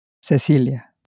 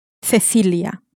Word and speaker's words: Cecilia: Cecilia.